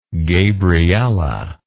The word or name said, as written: gabriella